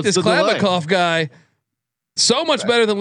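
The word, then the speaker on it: Kliavkoff: And first off, great pronunciation too, by the way. Have you been working on Klavikov? – You know, I'm drinking.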